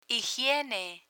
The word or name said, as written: higiene